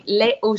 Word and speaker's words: Lait au: How do you pronounce the Light au Chocolat that sponsors some of your races up there? Because I have one of their stickers on a Gillies, and I've left it on there because I love it. I just don't know how to pronounce that. – You mean the milk chocolate? – Yeah. – Is that what it is? Okay. – Yeah, that's the milk chocolate. And in French, we say Lait au Chocolat.